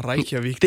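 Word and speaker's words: Reykjavík: Så hvis du til at spørge, hvordan det var anderledes, og ligesom være i, var det Trottua Reykjavik?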